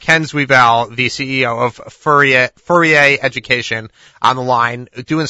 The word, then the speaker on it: Fourier: Tell me, Ken, is it the French pronunciation of Fourier? – Yeah, it's Fourier. It was actually named after the French mathematician Fourier.